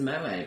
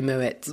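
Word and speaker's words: Moët: Because everyone says Moe, but it's actually... Because it's... Isn't it... – The T comes before an E. – Yeah. – So French. So it should be Moet. – Moet, yeah.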